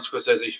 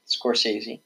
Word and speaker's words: Scorsese: One, I'm a huge Martin Scorsese fan. You know, Scorsese. – I know. I'm bad with names. I'm bad with names pronunciation.